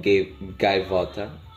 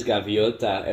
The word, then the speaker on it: gaivota: This is like a Portuguese joke because in Portuguese, a seagull is called gaivota, and then we say gaivota. – But in Spanish it's gaviota, and we don't say that.